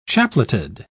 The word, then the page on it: chapleted: British English